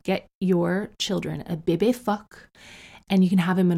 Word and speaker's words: phoque: Do you know what seal is in French, by the way? It's fuck. You spell it P-H-O-Q-U-E.